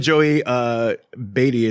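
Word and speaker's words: Joey Batey: Joey Beatty is, I believe, how you pronounce it. – I think so.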